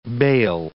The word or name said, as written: bale